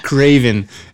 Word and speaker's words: Cravensworth: And then Matt Berry plays Laszlo Cravensworth. – Craven. Craving is really what you need to focus on there. Sex is what he craves.